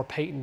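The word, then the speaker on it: Paton: You may, John Patton or Peyton, depending on how you pronounce it, from Scotland.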